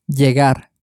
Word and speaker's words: llegar: You can probably hear the y sound at the beginning because it's spelt with a double L at the beginning, so it's pronounced according to the tortilla pronunciation rule. So to arrive in Spanish is spelt L-L-E-G-A-R and is pronounced llegar.